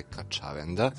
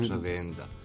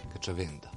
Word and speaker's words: Kačavenda: Po pravilima akcenat srpskog jezika je Kačavenda. – Kačavenda, ja sam... – Kačavenda, da. Što je jako čudno, ali ja kažem sladno sa svojim dijalektom, Kačavenda.